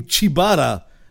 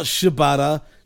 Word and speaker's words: Shibata: And second of all, he actually pronounced his last name properly. Unlike Dasha, who was calling him Chibata, not Shibata.